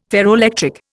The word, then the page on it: ferroelectric: Indian